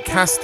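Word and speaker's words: Kasst: It's by The Cast, which is K-A-S-S-T. I think that's how you say it, Cast.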